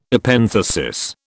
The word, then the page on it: Epenthesis: British English